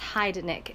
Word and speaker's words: Heidnik: Yes, Heidnik. H-E-I-D-N-I-K. – H-I-D-E... – H... No, start that over. H-E-I-D... – H-E-I-D... – N-I-K.